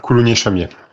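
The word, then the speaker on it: Coulounieix-Chamiers: Nous fonçons en Dordogne, dans un petit village d'ailleurs de 7832 habitants, Coulouniex-Chamier. Est-ce que je prononce bien ? – Bonjour, on prononce Coulounier, Coulounier-Chamier. – Coulounier-Chamier, voilà.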